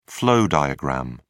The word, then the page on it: flow diagram: British English